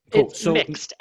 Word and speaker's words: mixed: Am I pronouncing it right? Mix it. You're going to have to dive a little deeper than that because I understood union types. I knew we were going to talk about that. I knew what those are. I don't know what this is. – Mixed, M-I-X-E-D. – Sorry, my pronunciation. Mix it.